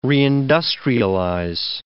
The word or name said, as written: reindustrialize